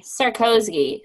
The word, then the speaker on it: Sarkozy: Sarkozy.